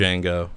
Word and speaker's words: Django: Django.